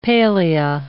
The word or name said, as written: palea